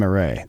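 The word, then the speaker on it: Marais: If it's M-A-R-A-I-S, it's Graham Array. Yeah. – Okay, great. Great. It's very Mardi Gras. It's got a Mardi Gras pronunciation.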